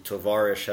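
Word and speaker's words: Tovarish (товарищ): Tovarish has a accent on the second syllable, where Domovich has the accent mostly on the last syllable.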